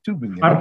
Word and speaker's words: Tübingen: Did I pronounce that correctly? – Tübingen. – Okay. Okay, thanks.